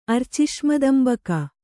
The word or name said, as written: ಅರ್ಚಿಷ್ಮದಂಬಕ